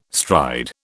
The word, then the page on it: stride: British English